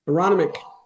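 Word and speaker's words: Aronimink: I never say it right. That golf course in Philadelphia there. Aronimac. Say it again. – Aronimac. – Yeah, that too.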